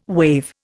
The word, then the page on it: wave: Indian